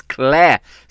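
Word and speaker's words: Clé: It's Claire. C-L-E is how you spell it.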